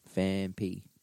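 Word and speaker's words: Phanpy: Fanfy or not Fampy? I don't know if it's Fampy or Fanfy.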